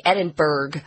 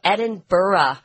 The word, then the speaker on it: Edinburgh: When I was growing up and I didn't know how to pronounce this city's name, I would say Edinburgh. Because we have a city in the United States called Pittsburgh, and it ends with the same letters, B-U-R-G-H. So Americans just think, oh, that must be how you pronounce it. But they actually say it differently in Scotland. They say Edinburgh, which is very different.